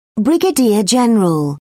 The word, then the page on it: brigadier general: British English